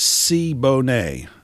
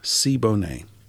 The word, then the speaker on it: Siboney: First of all, I pronounced it incorrectly. So Warren has corrected me. It's Siboney. Siboney.